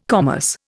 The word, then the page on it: commerce: Indian